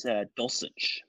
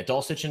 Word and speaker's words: Dulcich: But I will say I think Greg Dulcich, am I saying his name right, over in – I think it's Dulcich. Greg Dulcich in Denver. – Thank you. Yeah, Dulcich in Denver.